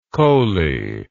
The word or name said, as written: koli